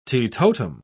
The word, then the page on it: teetotum: British English